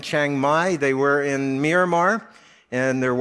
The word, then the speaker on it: Myanmar: So they had to leave Myanmar, that's how you say it, Myanmar, and then they're in Thailand there in Chiang Mai and seeing what the Lord will have them do.